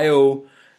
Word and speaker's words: Io: som hedder Io, inklusiv hvordan det skal udtales. Det udtales I-O.